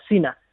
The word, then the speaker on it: Cena: Igual se pronuncia Sina, ¿no? John Cena. – John Cena. – John Cena, sí, pero se escribe Cena y vamos, que aquí en Cuenca y en Madrid decimos Cena, John Cena.